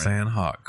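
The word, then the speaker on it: Sanhok: The Sank Hawk map will come... I don't know if I'm saying that right. – Sand Hawk, Chris.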